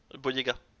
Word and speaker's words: Boyega: ça, John Boyega? – Boyega. T'as inversé les deux syllabes.